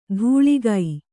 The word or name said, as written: ಧೂಳಿಗೈ